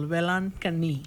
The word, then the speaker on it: Velankanni: Velankanni. – Velankanni. Okay, Our Lady of Velankanni. – Yeah, it's pretty difficult to pronounce the name, but it's very, very simple. Velankanni.